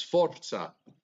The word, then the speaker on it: Forza: Roll your R. It's Forza.